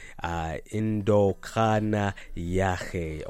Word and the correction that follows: Indodana Yahe: or rather I should say Indodana Yahe from Nandifa 808.